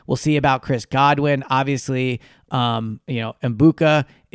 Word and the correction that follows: Egbuka: is a really good player, but or Egbuka, I should say.